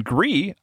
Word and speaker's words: GRIS: The developer behind Gree announced the game has sold over 1 million copies.